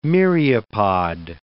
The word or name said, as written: myriopod